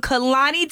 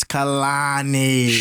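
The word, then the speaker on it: Kehlani: And if you want Kalani tickets. – That's not how you say her name, Shay. It's Kalani.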